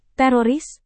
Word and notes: teroris: Indonesian

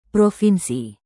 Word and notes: provinsi: Indonesian

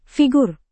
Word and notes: figur: indonesian

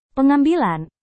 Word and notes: pengambilan: Indonesian

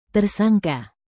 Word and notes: tersangka: Indonesian

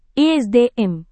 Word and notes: esdm: indonesian

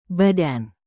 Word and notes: badan: indonesian

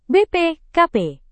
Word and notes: bpkp: Indonesian

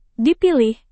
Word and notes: dipilih: indonesian

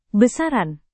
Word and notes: besaran: Indonesian

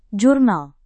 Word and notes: jurnal: Indonesian

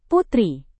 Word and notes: putri: Indonesian

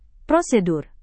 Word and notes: prosedur: Indonesian

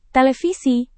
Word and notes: televisi: indonesian

